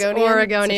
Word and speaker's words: Oregonian: Is that how you say it? – Yes, Oregonian.